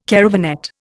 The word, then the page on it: caravanette: Indian